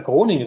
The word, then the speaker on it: Groningen: Äh, du hast sogar Groningen richtig ausgesprochen.